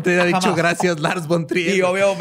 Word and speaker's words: von Trier: Gracias, Lars Born Tier. – Pero Eso no se dice muy seguido, por cierto. – Gracias, Lars von Trier.